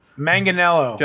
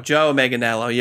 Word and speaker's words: Manganiello: I will butcher this man's name, although you know the face and you know him. – Manganiello. – Joe Manganiello. You're correct. – So we know him from True Blood.